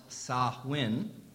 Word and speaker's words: Samhain: The pagan holiday of, and I want to show you, so it's spelled Samhain, but it's pronounced Samhain, okay? Although you can find some other pronunciations of it online, too.